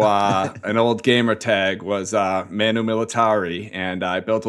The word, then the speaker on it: Manu: Am I saying it right? – Yeah, what? Manu.